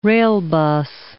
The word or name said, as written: railbus